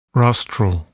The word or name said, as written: rostral